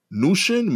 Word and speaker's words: Mnuchin: Mnuchin. – Mnuchin. M is silent, even though it's the biggest, it's the only capital letter.